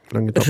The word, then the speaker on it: Languedoc: Languedoc. – Languedoc.